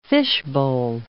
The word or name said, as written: fishbowl